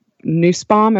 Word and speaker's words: Nussbaum: It's a very, if what we're really longing for is, and actually, the Nussbaum, am I saying that correctly? – Mm-hmm. You are indeed.